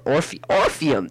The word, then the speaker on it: Orpheum: Is that Euphorium? Is that how they say it? Or Orpheum. There it is.